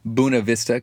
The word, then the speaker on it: Buena Vista: That's right, Buena Vista, even though it's spelled Buena. Buena Vista, Colorado, working with the Link School students and staff.